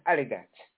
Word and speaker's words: Adidas: U.S. people know it as Adidas. It's pronounced Adidas. It's a German company.